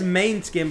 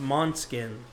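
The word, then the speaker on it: Måneskin: It says Main Skin, but I think it's pronounced Monskin. – Are they good? – Monskin, yeah.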